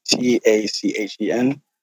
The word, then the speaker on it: Tachen: And so and it's actually uh, T A C H E N, pronounced as Tachen.